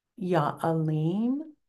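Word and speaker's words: Ya Alim: It is Yanur, which is N-U-R, but pronounced N-O-O-R. Ya'alim, spelled A-L-I-M, but pronounced A-L-E-E-M, and Yahai.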